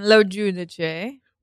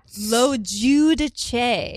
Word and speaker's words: LoGiudice: LoGiudice. – What? – What? LoGiudice.